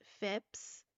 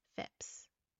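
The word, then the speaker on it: Phips: After the accusations against his wife and the pleas of the village, Phipps, I believe that's how you say his name. I wanted to say Phillips, but I don't think it's Phillips. It's P-H-I-P-S, Phipps.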